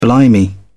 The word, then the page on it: blimey: British English